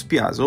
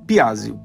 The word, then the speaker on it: Piazzi: Nos procure lá, é o nosso nome mesmo, Marcos Piazzi. Opa, o WhatsApp chamou aqui. É Marcos Piazzi. Piazzi se escreve P-I-A-Z-Z-I.